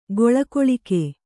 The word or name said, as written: ಗೊಳಗೊಳಿಕೆ